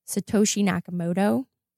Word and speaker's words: Satoshi Nakamoto: And the first one is going to be Satoshi Nakamoto. Did I pronounce that right? – Yeah, I think that was perfect.